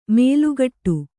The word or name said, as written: ಮೇಲುಗಟ್ಟು